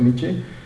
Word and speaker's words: Nietzsche: En 1886, un filósofo alemán llamado Friedrich Nietzsche, algo así se pronuncia Nietzsche, dijo: Dios está muerto.